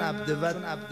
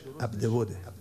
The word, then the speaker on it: عبد ود: چون عبد ود اون تلفظ مشهوره الله درستش عبد وده